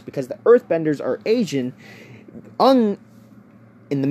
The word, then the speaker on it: Aang: It's supposed to be Aang, for crying out loud. They even pronounced it and showed it in the show. A-N-G, dummy. Aang.